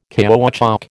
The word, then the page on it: caoutchouc: American English